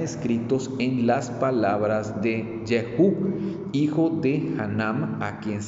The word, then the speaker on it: Jehú: Y también hay otro que, se menciona en 2 de Crónicas 20:34 de nombre Jehú, que se escribe J E H U, acento en la U les leo, hermanos, que dice eh, segundo de Crónicas 20 al 34.